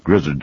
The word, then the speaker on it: Grizzard: There are people who spell G-R-I-Z-Z-A-R-D, and there are people who do pronounce it Grizzard. My family has never said Grizzard. I'm accused sometime of, now that I've written a book and so forth, they're changing it to Grizzard to Grizzard, but it is Grizzard. That's the way my daddy said it.